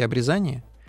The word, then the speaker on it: обрезание: Или, как предлагают нам говорить э, словари ударений, к обрезанию? – Ну, что касается словаря с ударениями, то я эту ситуацию подробно не изучал. – Ну, и это, мне кажется, да. что правильно говорить все-таки обрезание.